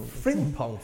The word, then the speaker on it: Frimpong: Frimpong. Am I saying that right? Frimpong. – Frimpong from Emmanuel Frimpong.